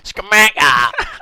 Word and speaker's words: Scamacca: Nah, just Scamacca on two goals, that's all I'll say. – How'd you say his name? – Scamacca!